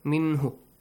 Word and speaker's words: مِنْهُ: أحسنتم مِنْهُ نو مِنْهُ short vowel sound